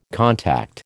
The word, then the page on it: contact: American English